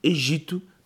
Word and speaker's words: Egito: Mudaram o nome do país de Egito, Egipto para Egito, porque supostamente o P é mudo. Mas depois é o Egito e os egípcios.